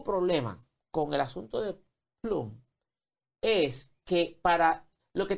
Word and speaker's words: Plume: Que no es otra cosa que un acuerdo que Liberty hizo con una compañía que se llama Plum. Plume se escribe, Plume.